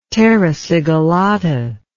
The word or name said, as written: terra sigillata